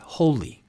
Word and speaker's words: wholly: The second piece I want to talk about is this word holy, and that's spelled W-H-O-L-L-Y, holy, completely holy.